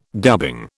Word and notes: dubbing: British English

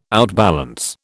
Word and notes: outbalance: British English

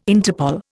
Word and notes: Interpol: Indian

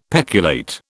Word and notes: peculate: British English

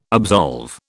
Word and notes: absolve: British English

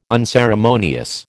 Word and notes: unceremonious: American English

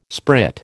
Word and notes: sprit: American English

